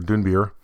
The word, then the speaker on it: Dunbier: And the big news, of course, is it's January 1st, so that means the 2023 Dunbeer Awards, or Dunbeer, I think is the proper way to pronounce it, are live, and voting is happening as we speak.